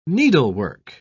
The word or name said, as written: needlework